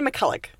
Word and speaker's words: McCulloch: How do you say your last name? I say McCulloch.